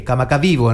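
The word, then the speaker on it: Kamakavivo (Kamakawiwoʻole): Kamakawi Ole, Kamakavivo en realidad se pronuncia.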